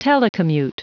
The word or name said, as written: telecommute